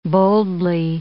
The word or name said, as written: boldly